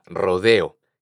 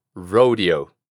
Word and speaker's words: rodeo: La primera pronunciación es rodeo. ¿Recuerdas esa R pirata de la que hablamos anteriormente? Pues vamos a hacer ese mismo sonido. Rrr. Ahora, las O las vamos a pronunciar así. O. O. Row. Row. Y en vez de decir la E como una E, vamos a pronunciarla como si fuera una I del español. Rodeo. No olvides pronunciar la última O como O.